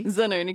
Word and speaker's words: Zanoni: When I graduated uni, they have professional pronouncers of your name. And so someone said, Jessica Zanoni. – Zanoni. – Zanoni. – Zanoni. – I never heard someone correct it with the proper Italian pronunciation before.